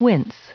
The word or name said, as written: wince